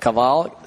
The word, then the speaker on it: Caval: Caval, C-A-V-A-L.